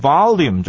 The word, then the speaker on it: volume: volume 这个字呢，我们在学的时候是念 volume 这个字啊、哦，但是呢，有不少的老美他们在念的时候会念作 volume，那个 L 的声音呢会消掉，念成那种 Y 的那个 E 的声音，volume 这种也是有可能性的。